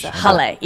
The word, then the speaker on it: jale: It's jail, or it's the other one. – J is with a, sounds like an H. – It's jale, yes.